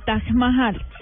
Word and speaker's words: Taj Mahal: Es el monumento más hermoso al amor, el Taj Mahal. – Se escribe T-A-J y Mahal, M-A-H-A-L. Taj Mahal, así se pronuncia.